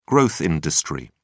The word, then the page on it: growth industry: British English